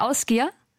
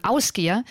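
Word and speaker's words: Ásgeir: Ist einer aus Island und ich finde immer so schwierig, weil der wird geschrieben Asgeir, was ja irgendwie so für uns eigentlich auch ein Wort wäre, aber ausgesprochen, mein Isländisch ist sehr beschränkt, aber ist wohl Ausgier.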